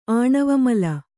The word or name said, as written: ಆಣವಮಲ